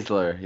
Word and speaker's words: Baszler: How do you say her name? Baszler? – Baszler, yeah.